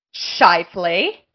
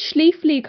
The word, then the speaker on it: Scheifele: Scheifele. – Where did Schlieffele come from?